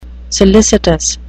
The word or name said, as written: solicitous